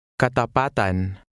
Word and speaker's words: katapatan: Remember to stress the second to last vowel, slightly lengthening the vowel sound. – Katapatan.